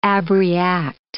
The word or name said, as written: abreact